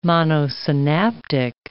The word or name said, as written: monosynaptic